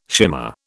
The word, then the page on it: shimmer: British English